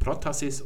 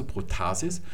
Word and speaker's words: Protasis: Und im Deutschen wird auf der Penultima sowas betont. Das heißt also Protasis und dort, wo betont wird, wird auch noch gelenkt und Apodosis. Das ist die richtige Betonung.